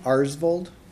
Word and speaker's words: Aarsvold: Tim Arsbold. – Could you spell that for us? – A-A-R-S-V-O-L-D.